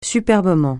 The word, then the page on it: superbement: francais